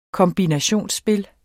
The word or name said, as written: kombinationsspil